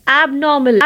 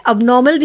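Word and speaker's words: abnormal: एबनॉर्मल एबनॉर्मल या फिर अबनॉर्मल भी प्रोनाउंस करते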